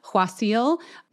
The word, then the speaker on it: Qasil: I think I had a guest co-host that time, Jess, so you might not remember this, but Huasil, if you pronounce it correctly, it has that kind of huh sound to it, but it's spelled Q-A-S-I-L.